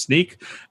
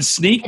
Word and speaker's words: Snyk: It is Sneak. And Sneak. – And how do you spell that? – Oh, yeah. – S-N-Y-K, which is why everybody gets it wrong.